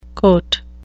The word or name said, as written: cote